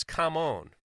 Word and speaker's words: Kamon: Pronounce Kamon. Don't forget it.